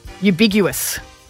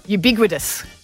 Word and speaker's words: ubiquitous: Ubiguous. – What? Say it properly. – Geez, you're very ubiguous, Kat. – Ubiguous or ambiguous? – Ubiquitous.